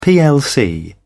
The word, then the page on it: plc: British English